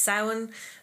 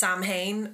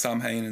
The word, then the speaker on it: Samhain: And it's not pronounced Samhain as it was in that show that got cancelled on Netflix recently, Sabrina, the remake.